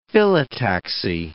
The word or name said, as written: phyllotaxy